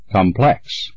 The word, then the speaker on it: complex: Complex. – The second syllable, plex, P-L-E-X, is correct. – Remove. – The second syllable, moved, M-O-V-E-D, is correct.